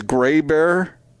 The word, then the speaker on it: Grabher: L-O-R-N-E, and his last name, I think the pronunciation of it is Gray Bear, but it's spelled, picture this in your minds with me, G-R-A-B-H-E-R.